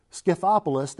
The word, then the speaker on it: Scythopolis: Scythopolis, that were also part of this Decapolis.